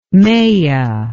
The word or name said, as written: Maia